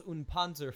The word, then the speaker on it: und Panzer: They've done the Arslan series, which also has a movie or two, the Girls und Panzer film, and...